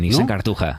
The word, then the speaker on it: Nissan Cartuja: Creo que lo he dicho bien, ¿no? – Nissan Cartuja.